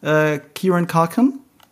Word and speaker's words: Kieran Culkin: Kieran Culkin.